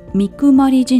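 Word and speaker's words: 水分: こうそういう意味合いあったなっていうのを改めて思い出しましたあとはですねこんな名前の神社があったということで水を分けるあの 1 分 2 分の分ですね神社と書いて三くまり神社と読む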